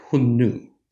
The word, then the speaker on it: Xiongnu: But the northern Huns, or the northern Xiongnu, uh, Xiongnu is not the phonetic transcription that the Han Chinese of the second century AD would have used. That's the modern Mandarin pronunciation of those same glyphs, right? So in Old Chinese and Early Middle Chinese, those glyphs would have sounded something like Hunnu.